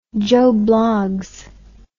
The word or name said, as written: joe bloggs